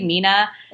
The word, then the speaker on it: Mina: I've definitely contemplated changing it though, just because I feel like for people who also don't know how to pronounce my name correctly, Mina, and everyone calls me Minna when they read off my name.